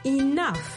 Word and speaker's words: enough: ça s'appelle E-N-O-U-G-H. Et bien sûr, ça ne s'appelle pas comment ça s'entend. Ça s'entend comme I-N-O-F, enough. Le G-H en anglais se prononce souvent comme un F. Et le O-U, en fait, ça se prononce comme un O, enough, enough.